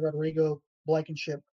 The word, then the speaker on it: Rodrigo Blankenship: And I think when you bring in a guy like Rodrigo Blankenship, pardon me if I mispronounced his name. – I think you got it. You're good.